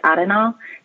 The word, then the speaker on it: Arenal: I think it's Arenal. – Yeah, I'm going to take your advice. – Well, I think in Spanish they pronounce it like Arenal.